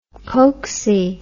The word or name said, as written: pokesy